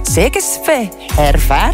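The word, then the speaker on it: Székesfehérvár: Het is de eerste keer vandaag dat onze dochter de hoofdrol danst in een ballet van Zeikes Fehervár in Hongarije. Super trotse papa. – Dat is exact juist uitgesproken.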